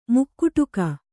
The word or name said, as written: ಮುಕುಡಕ